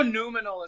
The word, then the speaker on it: noumenal: I know noumenal is how you say that word. Noumenal.